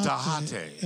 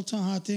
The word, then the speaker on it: Tejate: Tell it's spelled T-E-J. – T-E-J-A-T-E. – It's like Tecate, right? – Right. It doesn't have an address here. It's on Facebook. You can check it out. But just type in El Tejate in Carlsbad, Mexican restaurant.